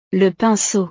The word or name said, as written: le pinceau